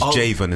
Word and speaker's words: Javon: You're still saying my name wrong. It's Javon. It's like Raven with a J.